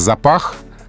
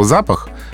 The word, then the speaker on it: запах: Да, есть такое слово «запах», которое выглядит точно так же, как слово «запах», но произносится с другим ударением.